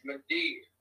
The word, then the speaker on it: Madere: It is Madeer. M-A-D-E-R-E. Madeer. Like a mama deer. – It's Madeer.